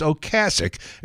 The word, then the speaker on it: Ocasek: And she said, it's Ocasek.